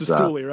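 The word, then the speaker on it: Cistulli: We know that Carson Casilli over on Fangraphs thinks that he's a future MVP candidate. Sorry, I totally butchered his name just now as I was Sistuli, right? – Yeah, Sistuli, as I was you know,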